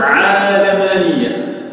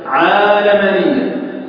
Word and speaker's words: عالمانية: قلت العلمانيه اسم مصطلح على مجموعه من الافكار ينتهي له البعض بطريقه معينه، اصلها انها تنطق عالمانيه. أصل الكلمة في اللغة العربية تنطق هكذا عالمانية نسبة إلى العالم وليست نسبة إلى العلم كما يقولون، لأن نسبة إلى العلم غير صحيحة لغة.